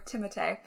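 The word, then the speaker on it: Timothée: They go on to talk about Timothy Chalamet, or Timotei, as his name is properly pronounced, which is so pretentious.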